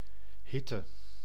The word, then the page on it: chaleur: France; [ʃa.lœʁ]